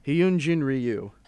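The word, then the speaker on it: Hyun-jin Ryu: Hyunjin Ryu.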